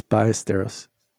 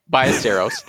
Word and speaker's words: Ballesteros: Once called the Sevi Trophy in honor of Sevi Ballesteros. And now it's known. – I believe it's Ballesteros. – Ballesteros. Thank you.